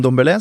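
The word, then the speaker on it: Ndombélé: En Dombélé? Fordi uh, presidenten til Lyon sier Dombélé. – Ja, men det er fordi på fransk så sløyfer man fort rask, en N.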